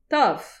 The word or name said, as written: tav